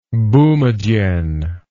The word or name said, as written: boumedienne